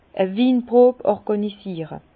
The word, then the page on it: e Winpro orgànisiere: Bas-Rhin; Herrlisheim